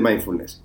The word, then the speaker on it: mindfulness: el capítulo de hoy en el, bueno, en el capítulo de hoy vamos a hablar de el mindfulness. ¿Lo dije bien o no? Sí, está bien. Mindfulness.